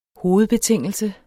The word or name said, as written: hovedbetingelse